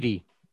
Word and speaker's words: Idi: how do you say his name? Idi? – Idi. – Yeah, Idi Amin.